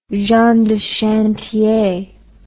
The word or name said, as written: gens de chantier